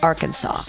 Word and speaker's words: Arkansas: But it's absolutely certain that the name as pronounced by the Indians was the name as if pronounced by our language, Arkansas, according to the publication of Arkansas Historical Association. The S on the end is simply a French addition, then a silent, and a silent one at that.